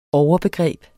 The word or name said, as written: overbegreb